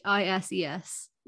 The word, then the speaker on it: Anchises: Aeneas was the son of a prince named Anchises. How did you say it? – Anchises. That's, yeah, basically.